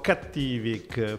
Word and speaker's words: Cattivik: No, allora, io dico Cattivic, perché okay, dovrebbe derivare dalla parola cattivo, come diabolic, da diabolico. Dopodiché ognuno lo chiama come vuole, va bene spostare l'accento su qualsiasi okay vocale, non c'è problema.